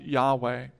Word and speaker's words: Yahweh: More modern scholars pronounce it Yahweh.